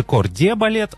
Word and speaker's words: corps de ballet: В словарях Ушакова и Ожегова, кстати, подчеркивается именно французский источник указанием, что нужно произносить «д». То есть пишется «corps de ballet», а произносится «д».